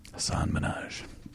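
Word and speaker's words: Hasan Minhaj: Hassan Minhaj.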